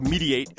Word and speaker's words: Mediate: An Italian-American group is up in arms about a comment by golf announcer Johnny Miller about Rocco Mediate, or Mediate as he pronounces it.